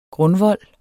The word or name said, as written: grundvold